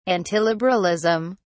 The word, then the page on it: antiliberalism: British English